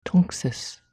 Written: Tunxis

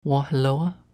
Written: Wawhillowa

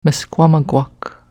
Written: mskwamagwak

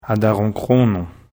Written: Ataronchronon

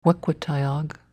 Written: Wequittayag

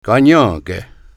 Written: Kanienkeh

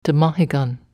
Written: tmahigan

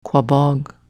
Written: Quaboag

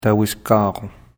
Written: Tawiskaron

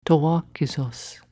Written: Towwa kesos

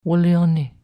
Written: wlioni

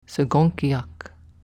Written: segonkiak

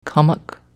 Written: Commucke

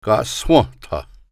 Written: Kahswentha

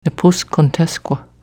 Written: Nepuscantesqua

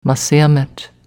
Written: Masseamet

